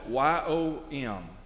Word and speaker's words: yom: The Hebrew word for days is spelled Y O M, yom.